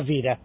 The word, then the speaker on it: vida: Per esempio a scuola pronunciem a scuola, la vida pronunciem la vira.